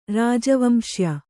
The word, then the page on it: ರಾಜವಂಶೀಯ: Kannada